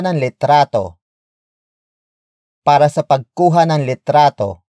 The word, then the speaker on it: litrato: Word for word, it's para sa pagkuha ng litrato. – para, sa, pagkuha, ng, litrato, para, sa, pagkuha, ng, litrato.